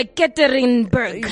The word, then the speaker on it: Yekaterinburg: Yeah, Yekaterinburg.